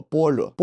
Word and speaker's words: 般若: 说完了此经书的译者和来历啊，我在此斗胆向各位听众讲述一下《心经》经名的含义。《心经》呢，全名是《般若波罗蜜多心经》。这一长串的音译到底有什么含义呢？听过王菲的念诵，大家都知道头两个字写作般若，而读作般若。